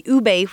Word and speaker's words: ube: So it's U-B-E, pronounced ube.